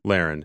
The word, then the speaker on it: Lahren: Tommy Lahren? – Lahren.